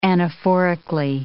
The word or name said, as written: anaphorically